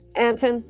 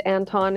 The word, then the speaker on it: Anton: Anton. – People. Okay. – Anton is kind of gotten anglicized. Anton is more Germanic.